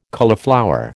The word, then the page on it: cauliflower: American English